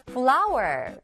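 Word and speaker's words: flour: 네, 이 발음이 꽃이란 발음이랑 같았죠. 바로 flower입니다. 스펠링은 달라요. flower. flower.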